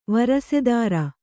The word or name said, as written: ವಾರಸುದಾರ